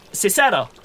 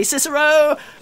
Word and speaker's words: Cicero: Cicero. Cicero. Ah! Who's asking? – I think it's pronounced Kikero, actually, but you know.